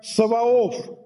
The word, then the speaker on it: Саваоф: Цебаот по-славянски произносится как «Саваоф», «бог Саваоф», «Элогим Цебаот», «бог Саваоф».